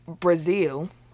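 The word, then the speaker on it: Breazeale: It was Deontay Wilder against Dominic Brazil. It's not spelled like the country, but it's pronounced the same.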